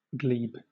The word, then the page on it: glebe: British English